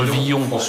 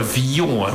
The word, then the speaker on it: Villon: Vous savez que François Villon. On dit François Villon, mon cher. – Villon. – On ne dit pas François Villon, en raison d'une rime célèbre sur laquelle je n'épiloguerai pas.